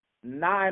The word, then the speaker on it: Nye: Did I pronounce Nye, Montana correctly, Chance? – Yes, you did.